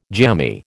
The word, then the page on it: jammy: American English